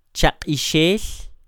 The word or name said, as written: chaq'ixel